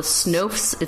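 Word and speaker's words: Snowths: These are They're called the Snoofs. It's spelled snow with THS after it.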